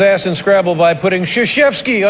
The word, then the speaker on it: Krzyzewski: You know, that's an interesting, and it's just fascinating, and it shows you how ignorant you can be, but the name is Krzyzewski. – Yeah. – Yeah, Krzyzewski.